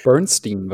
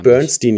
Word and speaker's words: Bernstein: Ich hoffe, ich habe das richtig ausgesprochen. – Bernstein wahrscheinlich. – Bernstein, ja, höchstwahrscheinlich. Der vor allem unter dem Namen Wreckful bekannt ist.